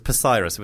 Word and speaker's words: Psyrus: We were called Cyrus with a silent P. At the front, it was Psyrus.